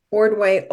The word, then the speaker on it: Ordway: So Holly, H-O-L-L-Y, Ordway, O-R-D-W-A-Y.